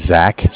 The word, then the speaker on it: Zach: All right, my name is Zach, spelled Z-A-C-H. – Okay. – I'm calling from Bank of Cardiff. Cardiff is spelled C-A-R-D-I-F-F, as in Frank Frank.